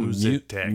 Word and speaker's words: Musitech: Musitech. – Oh, Musitech. Musitech. Okay. – Yeah. – That, it sounds a little like Mucinex? – Yeah.